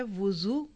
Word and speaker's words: वज़ू: याद रखिए दो लफ्ज़ हैं, एक है वुज़ू वाव की पेश, और एक है वज़ू वाव की ज़बर, और तीसरा लफ्ज़ है विज़ू वाव की ज़ेर के साथ। तीनों के प्रोनाउंसिएशन फ़र्क है।